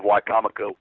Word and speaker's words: Wicomico: I must say, Dave, you really impressed me with your pronunciation of Wicomico,